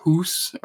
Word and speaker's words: Haus: In the new Vanguard, I really enjoy the map known as Das Haus, or Hus, or however you want to say it. – H-A-U-S, house. – H-A-U-S, house.